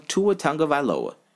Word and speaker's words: Tua Tagovailoa: Another guy that they created a narrative on, Tua Tagovailoa.